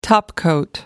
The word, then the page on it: topcoat: American English